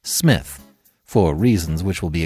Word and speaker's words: Psmith: Wodehouse and is the beginning of the novel Leave It to Smith, The P is Silent, part two of the Blandings Castle series.